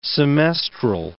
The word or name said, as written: semestral